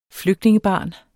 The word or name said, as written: flygtningebarn